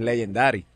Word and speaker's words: Legendaddy: Añade solamente el y se pronuncia igual que en inglés, pero es Legendary.